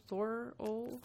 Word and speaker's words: Thorold: Is it Thorold? And he turned his head even though he has a slip disc right now and can't turn his head. He turned his head and was like, "It's Thorol." he, he, has family there, and for some reason, he's just so passionate about the pronunciation.